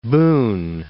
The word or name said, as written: Boone